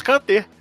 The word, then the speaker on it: Kanté: O Marcos Neco tá dizendo aqui que escreve-se Kate, mas que pronuncia-se Kanté.